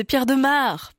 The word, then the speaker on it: Pierre de Maere: En fait, c'est Pierre de Maere, parce qu'il est belge. On prononce pas pareil que si c'était un umlaut, par exemple.